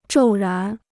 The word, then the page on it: 骤然: zhòu rán